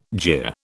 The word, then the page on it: jeer: British English